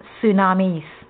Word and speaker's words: tsunamis: Tsunamis, pronounced tsunamis,